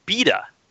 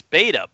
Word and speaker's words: beta: he pronounces it beta. And I was going to take issue with this because I would say, hey, wait a minute, beta? No, it's beta. But I read that in Britain it is beta. So, yeah. How do you pronounce it? – Yeah, beta. Yeah, that's how I pronounce it.